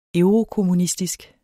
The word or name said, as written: eurokommunistisk